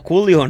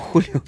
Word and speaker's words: Coolio: No, Coolio, no Julio. No, no, no, no. – ¿No lleva acento en la última O? – No, no, no, es Coolio, nada más.